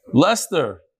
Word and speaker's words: Leicester: Oh, how do you pronounce it? Lester. That's how you pronounce it? Lester. Instead of L-E-S-T-E-R, L-E-I-C-E-S-T-E-R.